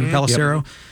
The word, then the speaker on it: Pelissero: Is it Tom Pelissero? Is that how you say it? Pelissero? Yep. NFL Network insider.